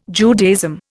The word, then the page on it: Judaism: Indian